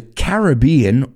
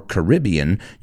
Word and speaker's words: Caribbean: And this guy, James Bond, was a Caribbean or Caribbean, you can pronounce it both ways, Caribbean or Caribbean bird expert.